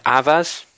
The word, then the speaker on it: Avaaz: The group, I think, is Avaz. I think that's how you pronounce it, AVAAZ if you want to check it,